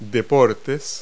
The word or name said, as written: sports